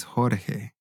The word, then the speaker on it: Jorge: So in Spanish, the pronunciation is Jorge. Jorge. Right. So the J has like a H sound, Jorge.